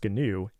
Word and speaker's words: GNU: It's pronounced GNU, and it's a project that predates the Linux kernel itself and was started all the way back in 1983 by Richard Stallman.